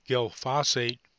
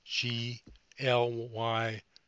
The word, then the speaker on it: glyphosate: While Bayer is courting Monsanto, Monsanto may see, I'll pronounce this word, glyphosate pulled from European use. That's glyphosate, pulled from European use and availability, following the refusal by the European Union nations to extend its license as a herbicide.